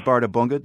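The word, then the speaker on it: Bárðarbunga: This one is Bardabunga. Did I get that correct? – You got that correct.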